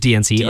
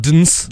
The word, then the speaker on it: DNCE: Is that how they say it? Or the Joe Jonas band. Yeah. I think it's just DNCE. Or you can call it Dns. It'd be kind of weird, but.